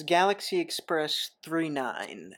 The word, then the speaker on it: Galaxy Express 999: It is Galaxy Express 39. That's how it's pronounced. It's spelled as Galaxy Express 999.